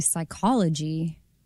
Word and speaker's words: psychology: The P is silent in psychology.